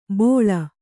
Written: ಬೋಳ

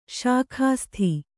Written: ಸೊಗಸಿತು

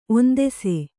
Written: ಒಂದೆಸೆ

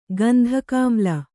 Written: ಗಂಧಕಾಮ್ಲ

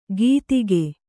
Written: ಗೀತಿಗೆ

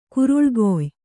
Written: ಕುರುಳ್ಗೊಯ್

